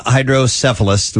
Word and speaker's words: Hydrocephalus: Well, the fifth annual Philadelphia Hydrocephalus, was I saying that right? – You are saying that correctly.